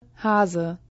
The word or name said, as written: Hase